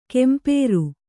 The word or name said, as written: ಕಂಪ್ರ